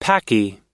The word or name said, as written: paki